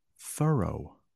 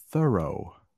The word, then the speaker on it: thorough: Also, thorough. Thorough. That's how you pronounce that.